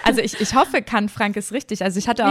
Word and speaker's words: Canfranc: Die WM in Canfranc. – Hast du schön ausgesprochen.